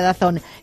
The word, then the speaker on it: DAZN: DAZN, pero ya sabes que se pronuncia Dazón.